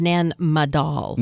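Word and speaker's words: Nan Madol: It's Nan Madal.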